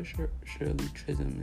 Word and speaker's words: Shirley Chisholm: Miss Shirley Chisholm. There we go. Shirley Chisholm is that. That was the first black woman to ever run for president. Okay? So I'm sure Shirley Chisholm is happy right now in heaven.